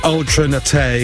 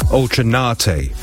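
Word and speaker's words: Ultra Naté: Ultra Nate is what I always used to call her. Ultra Nate. With the emphasis on the A. But it's not, it's Ultra Nate.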